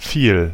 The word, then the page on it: much: [ˈmʌtʃ]